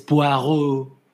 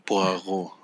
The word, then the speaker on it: Poirot: Ah, mira, sí es Poirot. Poirot, ajá.